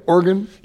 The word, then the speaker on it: Oregon: Did I say it right? Oregon? – You did say Oregon, right? Yeah. Yeah. A lot of people say Oregon. That's how we know you're from not around here.